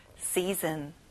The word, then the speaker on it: season: 여러분, 이를 길게 끌어보세요. season. That's right.